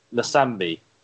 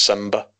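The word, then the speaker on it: Nsimba: And then, how do you pronounce this guy's name? I'd say Nsambi. Is that right? Webster? – It's just Simba. – Simba? – Yeah. – Like the lion? – Yeah. Okay. N silent. I don't know why it's there. You don't see it.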